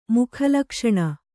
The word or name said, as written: ಮುಖಲಕ್ಷಣ